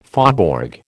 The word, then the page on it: faubourg: American English